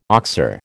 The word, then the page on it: oxer: American English